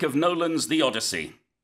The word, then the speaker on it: Odyssey: 그러니까 논란은 Nolan 감독을 얘기하고 있는 거고 그다음에 이제 오디세이라고 우리는 하는데 영어로는 Odyssey. – 네. Odyssey라고 해요. 그래서 요 발음 차이는 그냥 처음부터 그냥 기억하시면 될 것 같고요.